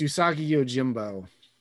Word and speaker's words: Usagi Yojimbo: It's Usagi Yojimbo.